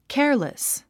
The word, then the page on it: careless: kéə r lis; ケアレス